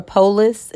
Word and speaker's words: Polis: And if you're in Colorado, you want Jared Polis, I believe, or Polis is how you pronounce his name.